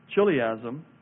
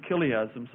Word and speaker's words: chiliasm: And then the last one, I suppose it's chiliasm. Maybe it's chiliasm. Sometimes you pronounce it like a K, but chiliasm, that's letter F.